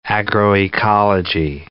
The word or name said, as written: agroecology